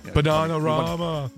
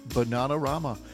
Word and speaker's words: Bananarama: Bananarama. – Bananarama.